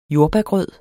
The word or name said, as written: jordbærgrød